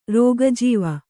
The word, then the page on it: ರೋಗಜೀವ: Kannada